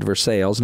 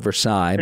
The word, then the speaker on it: Versailles: One of them I thought was interesting when you were walking through Versailles, which, you know, yes, in Kentucky we do pronounce it Versailles, not Versailles, but Indiana right. does the same thing, so we're not the only ones.